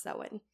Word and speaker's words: Samhain: It's spelled Samhain, but pronounced Samhain.